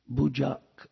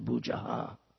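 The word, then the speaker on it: bhujaḥ: And when the first and third lines have an H with a dot under it, it's pronounced K, Bujak. But when the second and the fourth line have an H with a dot under it, it's pronounced Aha, like Pitavasas Chatur Bujaha.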